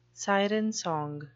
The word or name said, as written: siren song